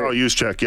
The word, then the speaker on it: Juszczyk: Yeah, Juszczyk.